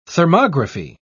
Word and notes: thermography: British English

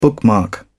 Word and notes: bookmark: British English

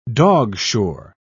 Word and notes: dogshore: British English

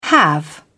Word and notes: have: British English

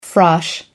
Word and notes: frosh: American English

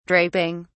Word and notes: draping: British English

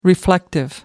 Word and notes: reflective: American English

